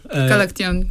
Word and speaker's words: Galaktion: Donc quand je lui ai demandé quelques chansons pour aujourd'hui, de suite elle a pensé à cet artiste, et notamment, donc, et je vais mal prononcer, sorry I will pronounce the name not as well as you, mais donc Galaktion Tabidze, Galaktion, exact,